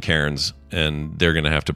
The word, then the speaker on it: Cairns: I know you're saying it's pronounced Cairns. Take it as an Aussie, Cairns. That's how you pronounce it.